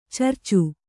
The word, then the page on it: ಚರ್ಚು: carcu